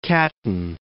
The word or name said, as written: Catton